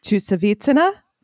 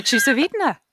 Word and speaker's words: Chusovitina: And speaking of tenacious and being around for a very long time, and I'm going to pronounce her name wrong, so you know who I'm talking about, Oksana Chusevitsina. – That was lovely. Oksana Chusovitina.